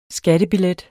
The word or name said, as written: skattebillet